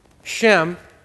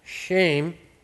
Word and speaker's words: Shem: Shem, more precisely pronounced shame, means name.